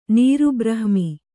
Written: ನೀರುಬ್ರಹ್ಮಿ

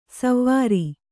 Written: ಸೌವೀರ

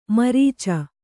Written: ಮುರಿಚು

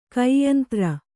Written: ಕೈಯಂತ್ರ

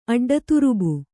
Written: ಅಡ್ಡತುರುಬು

